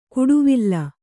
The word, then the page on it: ಕುಡುವಿಲ್ಲ: kuḍuvilla; Kannada